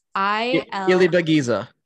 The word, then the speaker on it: Ilibagiza: I can't pronounce her last name, but it's Ilibagiza.